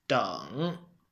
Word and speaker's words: 等: So which tone is this lesson's theme character? Deng. Well, it's third tone.